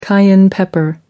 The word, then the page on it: cayenne pepper: American English